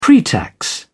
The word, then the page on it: pretax: British English